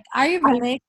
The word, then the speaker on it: Ayurvedic: Ayurvedic is an ancient,